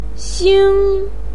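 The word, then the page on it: xing: tone 1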